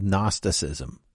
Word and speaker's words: Gnosticism: In this case, the false teaching was called Gnosticism, silent G, from the root word gnosis.